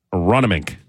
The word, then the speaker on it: Aronimink: Aronimink. That's how you say it.